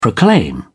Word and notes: proclaim: British English

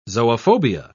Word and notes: zoophobia: British English

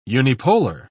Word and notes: unipolar: British English

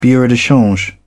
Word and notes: bureau de change: British English

